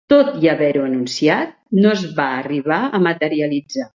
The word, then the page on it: materialitzar: Catalan